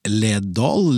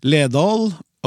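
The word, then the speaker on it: Ledaal: Som kjent uttales dobbel A som lyden Å, ikke A. Dette er vel en gammel måte å skrive på? Sikkert noe dere kan greie ut om. ja. Men Ledal, eh, Ledal er jo et slags akronym.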